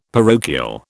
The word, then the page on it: parochial: British English